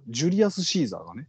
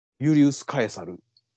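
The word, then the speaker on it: Julius Caesar: ジュリアス・シーザーがね。ユリウス・カエサル。これ、どっちが何語かわかんない。ジュリアス・シーザーは英語読みしたら、ジュリアス・シーザー。 – ほう。 – ユリウス・カエサルっていうのは、ラテン語の読み方かな。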